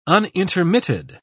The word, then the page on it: unintermitted: British English